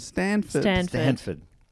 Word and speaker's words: Stanford: Am I saying this right? Sanford Prison Experiment? – Stanford.